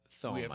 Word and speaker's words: Thome: Thome, I believe his name is pronounced.